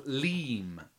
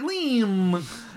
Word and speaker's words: Leem: So as you approach the town, all three of you will recall that this is the coastal town of Leem, L-E-E-M. – Like my name. – Like Leem.